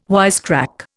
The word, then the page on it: wisecrack: Indian